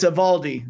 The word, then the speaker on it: Eovaldi: E-O-V-A-L-D-I. But the O is silent. Don't say Eovaldi. Don't be one of those. It's Evaldi.